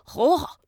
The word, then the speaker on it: Hella: And he goes, Oh, you mean Hola? I'm like, Okay, yeah, that's where I'm going. Hola. it's pronounced H E L L A. And he said, Oh, yeah, yeah, that, that, that part you have to do like a Hola.